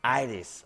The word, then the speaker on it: Aires: Aires. – I can't say it like you guys.